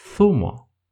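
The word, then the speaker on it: zumo: Когда мы видим эту букву, без всяких исключений, мы произносим ее как шепелявое «с». Например, башмак, ботинок будет называться «сапато». Сок будет thumo. Thumo.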